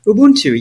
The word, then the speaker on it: Ubuntu: Ubuntu? – Ubuntu, yeah.